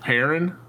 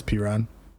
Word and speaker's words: Perine: It's not Perrin. Okay. – No, it's Perine.